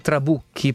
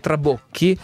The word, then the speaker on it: trabocchi: Alcuni dicono che si dice i trabucchi, però dalle parti nostre si dice trabocchi o li travoc, ok? Così almeno lo diciamo anche un po' con la sua pronuncia più corretta.